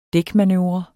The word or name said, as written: dækmanøvre